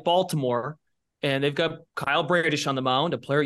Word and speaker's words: Bradish: I feel like you're the one who mixes us up, with all due respect, because I think we get it right, and then we just make the joke like, oh, it's Bradish. Oh, it's okay. Back it up. Bradish. That is his baseball reference pronunciation note.